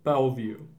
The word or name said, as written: Bellevue